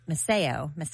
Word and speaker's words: Maceo: How do you say M-A-C-E-O? – Maceo? – Maceo.